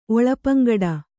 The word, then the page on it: ಒಳಪಂಗಡ: Kannada